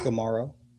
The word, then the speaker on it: Gumaro: Gumaro.